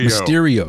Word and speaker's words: Mysterio: But the Mestro, is that how you say it? Mestro? – Mysterio, excuse me.